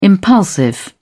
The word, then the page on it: impulsive: British English